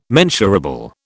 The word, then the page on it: mensurable: British English